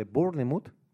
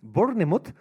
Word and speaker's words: Bournemouth: Bournemouth, ¿vale? Que sé que se pronuncia así porque... – ¿Cómo? – Bournemouth. Sí, porque hay un equipo de fútbol.